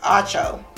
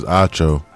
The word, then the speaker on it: Acho: Is that Acho or Ocho? Acho. – I think it's Acho.